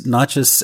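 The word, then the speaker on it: nachas (נחש): It's spelled without vowels, though. And there's a couple different versions which are represented with different vowel sounds, which is convenient for us readers. But the primary one here is nachas as snake, serpent.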